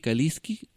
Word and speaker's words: Kaliski: Pueden, en cualquier buscador, por ejemplo en Google, pueden poner Enrique Kaliski con K de kilo e inmediatamente se va al sitio web.